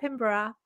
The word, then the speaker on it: Pinborough: Every time you say Sarah Pinborough, and America is very Sarah Pinborough, which I don't mind. I'm used to it. But when I hear someone in America saying it right, I'm like, oh, gold star for them.